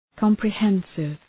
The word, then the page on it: comprehensive: {,kɒmprı’hensıv}